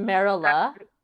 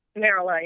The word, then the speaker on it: Merola: Like they perform an opera for those Merolini. – Mm-hmm. – Um, it's so pronounced that, Merola. Merola, yeah. Oh, I totally yeah.